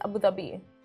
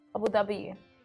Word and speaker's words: Abu Dhabi: And I don't think you nailed it. It's Abu Dhabi. Abu Dhabi.